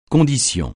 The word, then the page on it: condition: francais